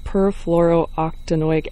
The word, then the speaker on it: perfluorooctanoic: Tim, can you start by, the chemical that we're talking about is called perfluorooctanoic acid. Am I saying that correctly? – Yes, you are.